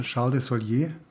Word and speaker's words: Charles de Solier: Ja, Charles de Solier.